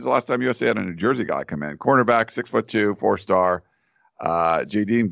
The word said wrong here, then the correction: Jaden: Gould. Is that right? How you say his name? – Jaden Gould, yeah.